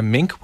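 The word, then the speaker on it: minke: While it could be a mink whale. Am I saying that right? – Yeah.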